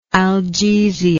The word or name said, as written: algesic